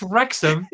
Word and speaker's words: Wrecsam: Pronounce this Welsh word for me. I'll spell it out for you. WRECSAM. – Is that Wrexham? – It's Wrexham in Welsh.